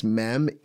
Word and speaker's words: mem: And so this one is pronounced mem, M-E-M.